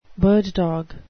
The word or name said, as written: bird-dog